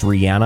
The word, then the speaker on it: Rihanna: Now, Jordan just corrected me. Rihanna is pronounced Rihanna. I didn't know that.